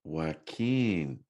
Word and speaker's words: Joaquin: Next bracket is Jason Statham and Joaquin Phoenix. Did I pronounce it right this time? All right, cool. Whew. Man, I know people call him Yo Quinn, or you know, somebody does.